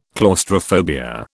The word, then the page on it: claustrophobia: British English